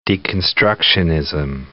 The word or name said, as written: deconstructionism